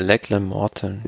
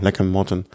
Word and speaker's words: Lachlan Morton: Äh, die Nummer von, du kannst sie besser aussprechen, McLagan. Weißt du, was äh, äh, Lachlan Morton. – Ja, genau, Lachlan Morton.